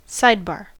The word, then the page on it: sidebar: American English